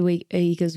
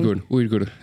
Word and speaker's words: Uyghur: Did you ever see what was happening with the Uyghurs when you were over there? Am I saying that right? I feel like I'm butchering. – Uyghur. And that's in their language, Uyghur.